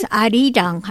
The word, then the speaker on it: Arirang: Ja, es Arirang heißt das.